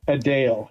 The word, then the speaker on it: Adele: That's how you pronounce her name, Adele. Everyone pronounced it wrong.